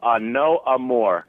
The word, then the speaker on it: A Nos Amours: And then N-O-S, you know, in French pronounced no. – N-O-S.